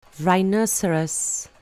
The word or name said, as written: rhinoceros